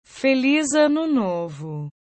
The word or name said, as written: feliz ano novo